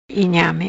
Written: Inhame

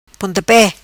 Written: Pontape

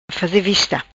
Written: faze vista

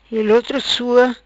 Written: Ilotro Sua

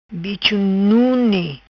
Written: Bicho Nune

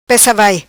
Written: Pessa Vai

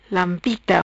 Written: Lampita

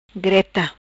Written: Greta

